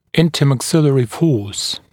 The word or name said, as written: intermaxillary force